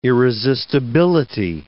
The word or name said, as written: irresistibility